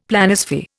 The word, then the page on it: Planisphere: Indian